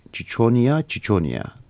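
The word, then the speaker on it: Ciconia ciconia: büyük beyaz göçmen kuş Çiçonia Çiçonia diye okunuyor Latince'de.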